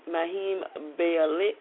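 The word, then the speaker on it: Mayim Bialik: Maheem Bialik, is how you pronounce it, The Big Bang Theory, and Anna Klumsky, Veep.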